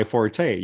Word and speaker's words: forte: You're not supposed to pronounce the E at the end. I'm like, everybody I know in America pronounces the E. – It's not my forte, yeah.